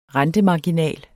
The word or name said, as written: rentemarginal